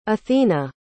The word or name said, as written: athena